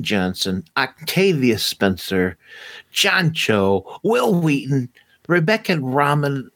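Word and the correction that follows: Romijn: Not ramen noodles, romaine.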